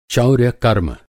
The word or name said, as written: चौर्यकर्म